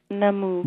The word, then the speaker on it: Namu: And I want to also have our listeners listen to, it is pronounced Manus, Manus Invocation. We're going to come back. – Namu. – With Namu an N, not an M. Namu. I reversed the letters there.